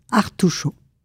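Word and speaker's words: Artout'show: Artuchot. Association Artuchot. – Qui s'écrit A-R-T-O-U-T apostrophe S-H-O-W.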